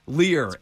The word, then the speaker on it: Lear: Lair Realty? Yes. I think I'm saying that right. – Lair Realty? – All right. – Is that his next job? Is that what he's... – Lear. L-E-A-R.